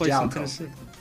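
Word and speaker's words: Jaleco: A-I-C-O-M. And published for the NES by Jellico? – I believe it's pronounced Jalco. – Jalco. – I was thinking Jellico. – Okay. Because we already covered a Jalco game like my podcast. So I've always said Jalco.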